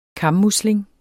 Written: kammusling

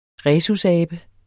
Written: RHESUSABEN

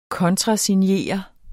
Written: kontrasignere

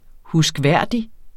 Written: huskværdig

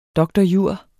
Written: dr.jur.

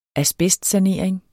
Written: asbestsanering

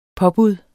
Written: påbud

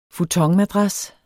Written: futonmadras

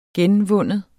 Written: genvinde

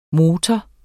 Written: MOTORERNES

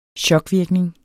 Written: chokvirkning

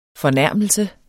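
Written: FORNÆRMELSERNES

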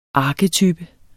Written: arketype

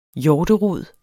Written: hjorterod

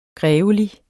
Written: grevelig

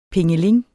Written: pingeling

